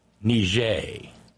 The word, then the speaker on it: Niger: But we have to pronounce it Frenchly. Niger. Because you damn sure don't want to do the Latin pronunciation, because that'll get every Klansman in America just singing Lee Greenwood or something.